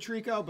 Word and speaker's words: Treecko: What's the correct pronunciation? – Treecko. – Okay. – He confirmed it the other day.